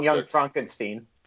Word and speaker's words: Young Frankenstein: How about Gene Wilder and Young Frankenstein? He's returning to his roots. – You mean Young Frankenstein? – Sorry. I'm as upset as you are that I mispronounced that.